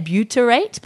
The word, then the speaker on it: butyrate: Butyrate? – Yeah, butyrate.